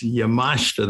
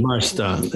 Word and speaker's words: Yamashita: One, my name is Yamashita. That's the best pronunciation. The I silent. – So Yamashita. Okay. – Yamashita.